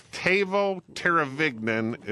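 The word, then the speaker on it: Teuvo Teräväinen: But I think it's Teuvo Teravainen is the way you pronounce it, certificate of authenticity.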